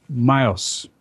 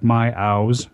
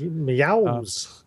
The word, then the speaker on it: Myows: My first one, I'm only mentioning it because I thought it had some very interesting capabilities, was Myos, is how you say it, although it looks like my ows. My, okay. So M-Y-O-W-S. – Meows.